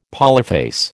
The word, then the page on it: polyphase: American English